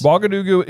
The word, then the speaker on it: Ouagadougou: Ouagadougou. – Ouagadougou. – Okay. – Yeah. – Real place. – Ouagadougou is a real place, and actually the reason I'm pronouncing it correctly is because I looked it up because I thought perhaps he was referencing was Ouagadougou, which is the wizarding school of Uganda.